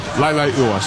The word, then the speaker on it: Leilua: We were told initially it was Francis Manu Leilua. It is Francis Manu Leilua. Leilua. So we've been set straight there.